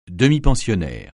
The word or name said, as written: demi-pensionnaire